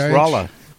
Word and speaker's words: Rolla: Is that spelled like Raleigh? How do you spell Rolla? – R-O-L-L-A. Rolla. – Rolla, okay. – Just like it sounds.